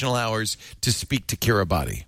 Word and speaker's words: Kiribati: It's pronounced Kiribati, but it's spelled K-I-R-I-B-A-T-I.